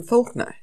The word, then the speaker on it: Faulkner: It says, murder your darlings is a popular piece of writing advice that is often attributed to William Faulkner. Am I saying that right? – Yes.